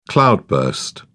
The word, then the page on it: cloudburst: British English